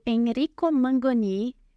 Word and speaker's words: Enrico Mangoni: Hoje estamos aqui com o nosso convidado Enrico Mangoni. Falei certo, né? Exatamente. é engenheiro civil pela Faculdade é,